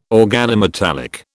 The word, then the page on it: organometallic: British English